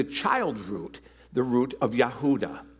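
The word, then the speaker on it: Yehuda (יהודה): This name, Judah, is pronounced Yehuda in Hebrew.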